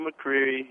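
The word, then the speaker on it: McQueary: But you did pronounce it right, McCreary, which is exactly what he is, not queer as in homosexual, a queer bird.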